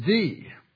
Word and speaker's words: ye: So you should never, as an educated American, ever pronounce it ye. It is the.